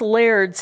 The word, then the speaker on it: lairds: And lairds is simply the Scottish pronunciation of the English term lords. So Knox was tutor to two sons of Hugh Douglas of Longniddry, and he also taught the son of John Cockburn of Ormiston.